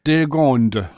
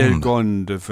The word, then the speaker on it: Delgonde: Also dann so Delgonde ausgesprochen, ja? – Delgonde. – Ach, Delgonde. – Delgonde, würde ich sagen.